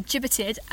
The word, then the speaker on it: gibbeted: The last person to be gibbeted, and that's how you pronounce it. I googled it.